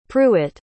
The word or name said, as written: prewitt